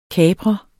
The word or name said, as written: kaprer